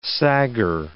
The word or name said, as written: sagger